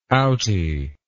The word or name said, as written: outie